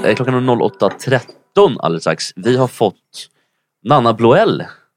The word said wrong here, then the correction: Blondell: Det är mycket enklare. Nanna Blondell.